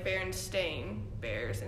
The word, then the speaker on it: Berenstain: But it's spelled Stain, so it's like Berenstain Bears instead of Berenstain Bears.